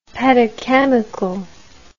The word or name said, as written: pedochemical